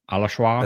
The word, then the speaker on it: Alashwa: Oh, it's like Alaswa or Alashwa. – Oh yeah, okay. Alashwa. – Alashwa. – I think is how they say it in the audiobook.